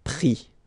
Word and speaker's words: pris: And then prendre, so E-N-D-R-E will give I-S, pris, okay? Same thing here, you don't pronounce the final S, okay?